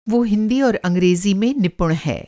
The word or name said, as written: निपुण